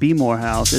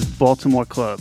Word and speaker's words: Baltimore Club: It's not Baltimore House. It's not Be More House. It is Baltimore Club. That is the proper way to say it from a real Baltimore native. So don't get it twisted.